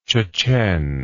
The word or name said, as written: Chechen